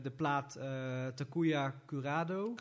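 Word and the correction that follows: Kuroda: Curoda. – Curoda. – Rode JC, hè? Rode JC. Rode JC. Ja, daar had je het ook kunnen herinneren. En het moet wel iets meer met uitspraak, man. Je doet het te Nederlands.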